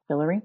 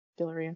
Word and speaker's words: Guillory: Leo, the Proposal by Jasmine Gil Gilleroy? Guillory, Did I say that right? Guillory, I think, yeah.